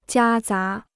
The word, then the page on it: 夹杂: jiā zá